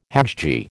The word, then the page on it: hajji: American English